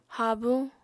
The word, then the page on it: habuñ: Apatani